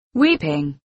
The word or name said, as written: weeping